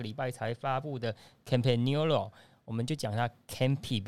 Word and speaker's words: Campagnolo: 呃，最早就讲 Campagnolo 啊，然后后来我是去了意大利之后，原来意大利人是 Campagnolo 哦，这是意大利人的念法。